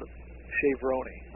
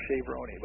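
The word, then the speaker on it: Chez Vrony: But it's called Chevroni, or Chevroni, but it's spelled C-H-E-Z, and then next word is V-R-O-N-Y.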